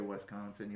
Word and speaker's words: Wisconsin: You got to say Wisconsin. You almost have to put the emphasis on the second syllable.